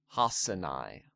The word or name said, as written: Hasinai